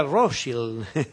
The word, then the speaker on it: Rothschild: Edouard de Rothschild, pronunciado en francés, y uno diría Rothschild en inglés.